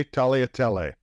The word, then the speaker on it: tagliatelle: I don't believe you pronounced the G. I believe it's tagliatelle. – Okay, tagliatelle.